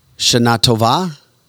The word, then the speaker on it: Shana Tova: Oh, a belated, here we are, phonetically, Shana Tova.